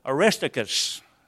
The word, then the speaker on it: Aristarchus: He mentions Aristarchus, that's how it's said.